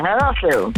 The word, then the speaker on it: Haslev: Udtalte jeg det rigtigt nok, Haslev? – Hej, Haslev. Ja, okay.